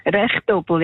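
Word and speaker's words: Rehetobel: Aber ich kann mir sagen lassen, Rehdoppel sagen Sie dort, gell? – Rehdoppel, ja, Rehdoppel. – Sagen Sie da.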